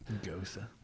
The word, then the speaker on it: Gose: I always, it's just spelled G-O-S-E. And so I always space on how to, because I had to ask the guys, like, I want the Chilton, how do you say that again? And he knew what I meant. Oh, Gosa. I'm like, okay, good. Because I always want to say Gose.